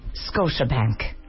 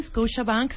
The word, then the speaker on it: Scotiabank: Scotiabank. ¿Lo dije bien? – Perfecto. – ¿O cómo lo debería haber dicho? – Muy bien, Scotiabank, se entiende, claro, preciso, no es un Scotiabank, no es un Scotiabank.